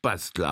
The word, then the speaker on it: Bastel: Jawohl, denn Sebastian wird auf Altenburgisch Bastel ausgesprochen.